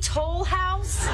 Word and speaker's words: Toll House: My grandmother said she got the recipe from her grandmother, Nestlé Toulouse. – What was her name? – Nestlé Toulouse. – Nestlé Toll House? – There you go. – You Americans always butcher the French language.